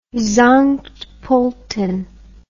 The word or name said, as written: Sankt Polten